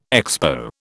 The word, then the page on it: Expo: British English